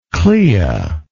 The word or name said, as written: clea